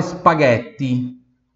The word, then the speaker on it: spaghetti: G-H-I-A-C-C-I-O. Ghiaccio. Próxima palavra, spaghetti. Spaghetti. Spaghetti. Spaghetti. Pronúncia pura do T, tá? Não é espaguete. É espaguete. Espaguete. Tá? Então, tem o S-P-A-G-H-E-T-T-I. Então, onde tem o G-H-E, Ghe, espaguete.